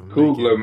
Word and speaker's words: Coogler: So we got Ron Coolidge is in the process of Coogler, man. – Coogler, my bad.